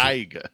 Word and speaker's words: Taiga: And Tiger, not Tiger, because he's African-American, Faust, so you need a little funk to it. And then they play... – You gotta spell it T-A-I-G-A.